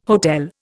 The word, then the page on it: hotel: Indian